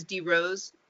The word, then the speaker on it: DeRose: Debbie Ann is spelled D-E-B-B-I-A-N-N-E. And my last name is DeRose, D-E-R-O-S-E.